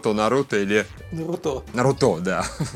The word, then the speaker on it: Наруто: счастлив, а потом оказалось, что вот официально Наруто э, я вот только когда ролики делал, и делал эту базовую озвучку, по которой потом уже профессиональные актеры все переозвучивали. Ага. Я сначала Нарутон, Нарутон, Нарутон, потом, бля, тут официально не так, а просто последние раза три уже Наруто в комментариях э, к тексту, типа, произносить на, на средний слог.